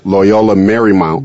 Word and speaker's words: Loyola Marymount: Loyola Marymount.